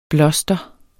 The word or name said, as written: bloster